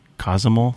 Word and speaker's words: Cozumel: Am I saying that right? Cozumel? – Sounds right.